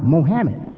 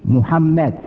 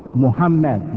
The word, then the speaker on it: Muhammad: Some people think my name is supposed to be pronounced Mohammed. They don't know that the phonetic pronunciation, M-U and the A-D at the end, is the correct pronunciation, Muhammad.